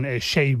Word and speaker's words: Seyi: Pasa, por ejemplo, con Shay Vibes, ¿no? Que se escribe Seiyi, S-E-Y-I.